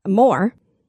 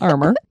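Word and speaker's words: Armour: At this time in America, the the first meatpacking plant, Moore, but I love meat, so it's a Moore. – If you want to call it a Moore, you can, even though I think it is pronounced armor.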